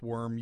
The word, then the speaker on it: Wurm: And I know that sounds like a strange name, but his name is Erwin Worm, and his last name is spelled W-U-R-M.